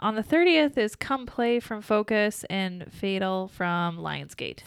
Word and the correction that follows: Fatale: Sorry. I always say it wrong. – Fatal. – Fatale. From Lionsgate.